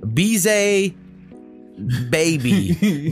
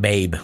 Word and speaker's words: Beezay Baybe: Now, he sent me phonetically how to say this, and I didn't write it down, so my bad. Bize Baby, but it's spelled B E E Z A Y B A Y B E, but I think it was Baby. Not babe, like you would maybe assume. So anyway, end game, you said? – End game.